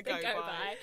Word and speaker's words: Gobi: If you're in New Zealand, it's pronounced the go by.